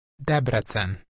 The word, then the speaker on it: Debrecen: Now you want to tell her that you're going for a few days to Debrecen.